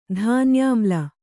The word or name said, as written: ಧಾನ್ಯಾಮ್ಲ